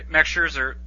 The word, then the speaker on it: Scherzer: Okay, Max Scherzer. Sorry, I do the correct German pronunciation.